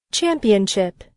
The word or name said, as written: championship